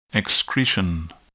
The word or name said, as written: excretion